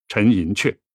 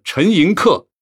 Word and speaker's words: 陈寅恪: 其实根据他自己的英语拼写，他是知道这个字的标准读法是读“ 克” 的，但是根据考证。陈先生的方言受到客家话的影响，会把这个字习惯地读成“ 阙”。根据名从主人的原则，我们一般会把他的名字读成陈寅恪。当然，您念成了陈寅恪也不算错。